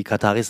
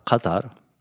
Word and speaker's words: Katar: Also die Kataris sagen Katar und Katari mit so einem Kaf. In England, Amerika sagt man wirklich Katar.